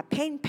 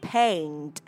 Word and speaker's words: paint: Đó là mình không đọc là paint paint giống như âm E ở trong từ pen của cây bút, mà mình đọc là paint ha.